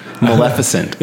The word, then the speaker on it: Maleficent: We're not saying it right. Maleficent.